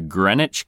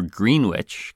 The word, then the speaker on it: Greenwich: And for this one, we're traveling on over to Greenwich, Connecticut. Classy. Or Greenwich, Connecticut. That's how I prefer to pronounce it.